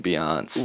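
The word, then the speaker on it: Beyoncé: I forgot you don't pronounce the last E in Beyonce.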